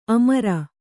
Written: ಅಮರ

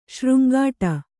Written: ಶೃಂಗಾಟ

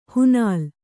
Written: ಹಣ್ಣೆಲೆ

